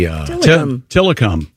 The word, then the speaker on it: Tillicum: I'll spell it for you, see if you can figure out the way it's pronounced. T-I-L-L-I-C-U-M. Would that be Tillicum. – Tillicum. Okay. – Yeah, not till I come. – Tillicum. – C-U-M.